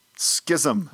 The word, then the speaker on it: schism: How do you say the word S-C-H-I-S-M? – Schism. – Schism? – Schism. – I'm with you. Schism.